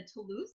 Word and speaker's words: Toulouse: The other thing that I did bring in from you guys, and tell me if I'm saying this right, the Toulouse.